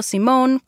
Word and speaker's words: Simón: sí, estoy pronunciando Simón con una E al final, como nombre femenino.